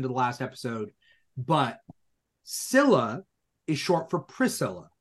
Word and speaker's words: Scylla: Now, Scylla could also be Scylla, but in English, supposedly, I was doing some research. It's pronounced Scylla, Scylla.